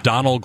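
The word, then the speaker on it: Domhnall: Donald, I think it's just pronounced Donald Gleason.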